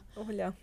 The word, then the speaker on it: Olga: My daughter calls you Ola. Oh, Olga is like it barely gets the G in there, and yes. I'm like, that is closer to the way you're supposed to pronounce it.